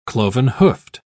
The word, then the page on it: cloven-hoofed: British English